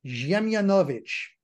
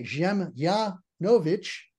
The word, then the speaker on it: Dziemianowicz: In Polish, I would be Stefan Ziemianowicz. So you run that D-Z-I-E-M together as Ziem. You put the accent on the I-A, Ziemianowicz. And, you know, W-I-C-Z in Slavic being pronounced Vich.